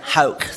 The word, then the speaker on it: Hauch: Hauch. So Hauch. It's a German name, oh. So that's why. So, I'm used to everything from Hooch to Hauch and all these things, but Hauch is the way, but as long as people can understand it, that's what counts.